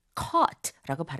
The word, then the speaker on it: caught: caught, c-a-u-g-h-t, caught라고 발음하게 되고요.